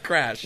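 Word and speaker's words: Kracch: It starts with a K too. So, yeah. that's got a soft C in it. – That's how you know it's fantasy. – Starts with a K and has a soft C. – Starts with a K, a soft C. How do you spell that then? – K R. – Yeah. – A C C H. – But it's pronounced Crash.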